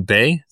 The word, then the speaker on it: Beay: Bay. The last name is spelled B-E-A-Y.